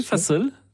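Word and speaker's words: fasıl: Alors, vous me rectifierez pour la prononciation. – Je vais essayer. – Vous proposer d'écouter un fa-sol. – Oui, fa-sol. Ça veut dire concert en turc, si on le dit comme il faut.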